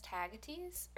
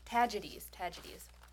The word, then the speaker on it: Tagetes: So its genus Tagetes, or I'm sorry, I pronounced that wrong, Tagetes.